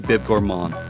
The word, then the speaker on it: bib gourmand: Listen, you'll always be my bib gourmand. – Very nice. Way to not pronounce the D.